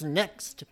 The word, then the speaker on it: Next: It's Too Close by, I believe it's supposed to be pronounced Next, but it's NXT.